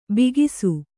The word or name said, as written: ಭಾಗಸ